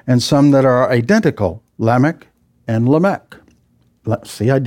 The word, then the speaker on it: Lamech: Well, I'm inclined to call it Lamech, but it's supposed to be Lamech. It's the emphasis on the first syllable, Lamech.